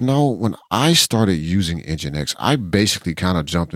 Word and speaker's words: NGINX: NGINX. Yeah, that is how you say it. I'm sorry. I hear gink so much. I was like, wait, that's not how you say that. I should really go fix that pronunciation because, man, I really got stuck.